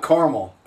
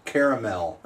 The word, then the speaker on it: caramel: Not caramel, caramel. – That's how we say it on the East Coast. – That's East Coast pronunciation.